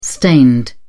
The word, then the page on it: stained: British English